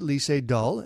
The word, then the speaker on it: Lee Sedol: That's how I would pronounce it, Lee Sedol.